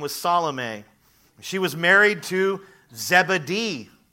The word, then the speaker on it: Salome: Also present was the sister of Jesus' mother, Salome. It is not pronounced salami.